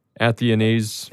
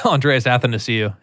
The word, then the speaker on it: Athanasiou: Athanasiou. – Yeah, Andreas Athanasiou.